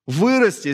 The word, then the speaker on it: вырасти: вырасти. Здесь ударение на вырос, кстати.